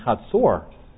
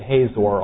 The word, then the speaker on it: Hatzor: I know some of you have pronounced it Hazor all these years, and so I'm pronouncing it a little differently here.